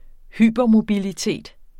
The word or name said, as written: hypermobilitet